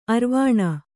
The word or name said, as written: ಅರೆವೆಣ್ಣು